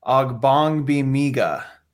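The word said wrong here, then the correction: Ogbongbemiga: I still screwed that up. – Ogbonbemiga. Yeah. – Ogbonbemiga. Amen. Yeah. Okay. I spelled it out phonetically and I still screwed it up.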